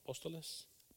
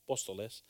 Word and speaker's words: apóstoles: Y unos puso Dios en la iglesia primeramente apóstolos, do we say it right? Apóstoles? Apóstoles.